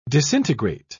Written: disintegrate

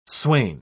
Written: swain

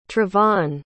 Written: trevonn

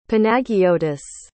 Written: panagiotis

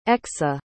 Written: exa

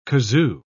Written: kazoo

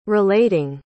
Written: relating

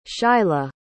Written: shylah

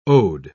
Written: ode

